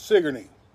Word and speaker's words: Sigourney: It's spelled like Sigourney Weaver, but it's Sigourney.